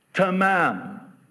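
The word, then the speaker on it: tamam (تمام): It's the same word in my native tongue of Arabic pronounced tamam.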